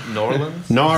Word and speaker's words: New Orleans: New Orleans.